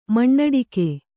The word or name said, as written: ಮಣ್ಣಡಿಕೆ